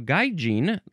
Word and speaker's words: gaijin: Next is gaijin, or could also just be gaijin. So gaijin, that's the first pronunciation, so that's what I'll say. G-A-I-J-I-N, gaijin, noun from 1964.